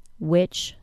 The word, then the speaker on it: which: Which, spelled W-H-I-C-H, is pronounced the same as which, W-I-T-C-H.